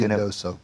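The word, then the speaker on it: Ruidoso: David, Redoso Downs, New Mexico. I don't think I pronounced that correctly. – Riadoso.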